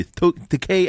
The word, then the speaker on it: Takei: If you're going to be doing a George Takei impersonation, you have to say the name properly. Takei as an OK. – And I was always told it rhymes with DK.